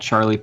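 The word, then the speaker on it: Charley: The best way to hit me up is definitely going to be on Instagram, and you can look me up at Charlie Pangus. My name is spelled L-E-Y at the end, and then my last name's P-A-N-G-U-S.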